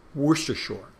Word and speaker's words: Worcestershire: Some people call it, because you have Worcester, Massachusetts, and they don't pronounce the R, you know. But Worcestershire is Worcester in Britain, and shire is the word for little village or town. But that's where it's from, the sauce is from. But we pronounce it Worcestershire.